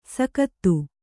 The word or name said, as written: ಸೈಕತ